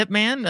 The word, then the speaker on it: Ip Man: It's from a movie called Ip Man. It's pronounced IP Man.